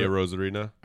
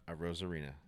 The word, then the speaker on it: Arozarena: Randy Arozarena. – Arozarena.